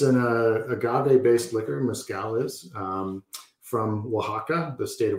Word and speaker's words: mezcal: So is it mezcal? Am I pronouncing it right? – Sure. That sounds good to me.